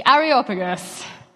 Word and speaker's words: Areopagus: Okay, so we're going to dive right into some of the context, because I read this and thought, who are the Areopagus and how do you say that word? and I Googled it and listened to it on YouTube. Yes, I did. So the Areopagus, how it's said apparently,